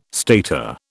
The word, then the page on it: stater: British English